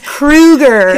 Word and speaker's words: Krueger: Cougar. – He's not a cougar dating young guys. – Am I saying it wrong? Is it Krueger? – It's Krueger.